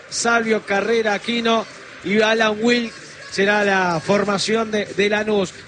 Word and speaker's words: Wlk: O sea, quedamos en Wick. – Se pronuncia Wick, exactamente.